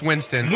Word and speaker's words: Winston: It's Winston.